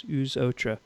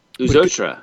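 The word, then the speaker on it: Aux Autres: Two words. Uzotra. Wow. Yeah, I would never have known how to pronounce that. – Uzotra.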